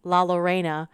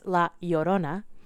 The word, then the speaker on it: La Llorona: As I said, I've heard pronounced La Lorena, which is a choice. Mm-hmm. More well known as La Llorona, mm-hmm.